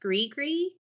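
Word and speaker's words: gris-gris: I also learned it's spelled gris, gris, like G-R-I-S. -DRIS, but it's pronounced gree-gree.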